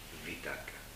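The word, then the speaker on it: vitarka: Esa atracción, atraer la mente a donde uno quiere, llamamos nosotros vitaka o vitaka. Pero es escrito con una V, ¿no? Vitaka, llamamos, y se pronuncia así en sánscrito.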